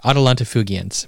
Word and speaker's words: Atalanta Fugiens: Okay, Atalanta Fugiens. Am I saying that right? – Yeah.